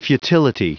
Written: futility

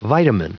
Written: vitamin

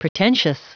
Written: pretentious

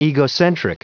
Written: egocentric

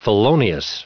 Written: felonious

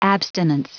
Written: abstinence

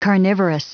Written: carnivorous

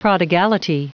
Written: prodigality

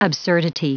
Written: absurdity

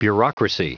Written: bureaucracy

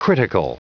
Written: critical

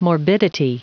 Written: morbidity

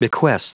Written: bequest

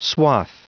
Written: swath

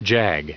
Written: jag

